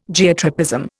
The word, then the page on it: Geotropism: Indian